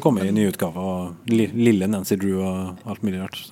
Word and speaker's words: Nancy Drew: For jeg leste om Nanki Drev, mm. og sa til min mor at Nanki Drev er så morsom. Og så sa hun det uttales Nancy Drew, altså.